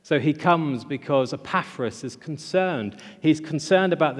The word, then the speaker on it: Epaphras: Paul's friend, Epaphras, I'm probably saying that wrong. How would you say it, Simon? – Epaphras, I knew. I've even got it written out in different ways to try and get it right. Epaphras, let's do that.